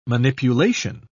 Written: manipulation

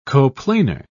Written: coplanar

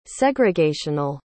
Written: segregational

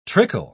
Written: trickle

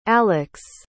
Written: alyx

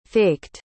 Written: faked

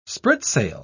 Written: spritsail

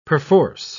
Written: perforce

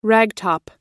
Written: ragtop

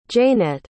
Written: jaenette

